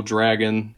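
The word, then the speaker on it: Drageaux: Well, of course, the person that you release is actually the bad guy, and it's this big buff purple dragon. And I don't know if he's from Louisiana or not, because his name is Drago, but the last the go part is like, it's spelled G-E-A-U-X, so he's Cajun.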